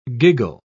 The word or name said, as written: giggle